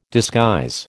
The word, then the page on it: disguise: American English